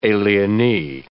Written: alienee

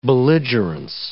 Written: belligerence

